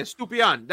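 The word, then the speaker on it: Estupiñán: How do you pronounce it? Your wife's Latino? – Yeah, Stupinan. That's what I'm going with, all right?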